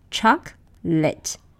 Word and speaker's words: chocolate: But that's not the way native speakers pronounce it. They pronounce it choc-lit.